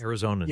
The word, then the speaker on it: Arizonans: Arizonans, yes.